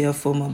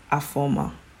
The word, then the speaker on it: Afoma: That's like, if you read it directly with the alphabets in English, you'll say Afoma. But the real pronunciation is Afoma.